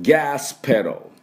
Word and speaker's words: gas pedal: プッププでペロで、このペダルと書いてあるんですが、 A をほとんど発音しません。だからペダルではなくてペロっていうふうに発音します。まあ、半母音でちょっとあっていうのが入ってもいいという辞書もありますが、ほとんど出しません。だからペロっていうような音になります。だからギャスペロ、この音です。じゃあ私の後ですね、発音いきましょう。ガスペロ、ガスペロ、はい、いいですね。